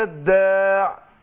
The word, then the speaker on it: الداع: تتنطق إزاي؟ عشان ما حدش ينطقها ياء. أقول دعوة الداع، دعوة الداع، دعوة الداع.